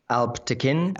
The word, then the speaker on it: Alp Tekin: Alp Tekin.